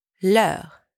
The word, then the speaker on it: leurs: There are two ways to say their in French leur and leurs. So yes, you heard that right. They do sound exactly the same, but one has a silent S and the other doesn't.